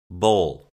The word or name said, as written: bowl